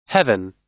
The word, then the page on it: heaven: American English